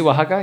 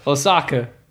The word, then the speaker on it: Oaxaca: Oaxaca? You didn't know how to say it before? O-A-X? – How did you say? I think he said Oaxaca. – Oh, yeah, that's what I said. Oaxaca. Osaka, that's in Japan.